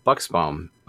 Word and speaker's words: Buxbaum: Michael Buxbaum. Did I pronounce that correctly? I'm sorry, I didn't ask before we... – You got that perfectly. I usually get Buxbaum, but Buxbaum, you got that right. First time.